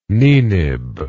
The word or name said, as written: Ninib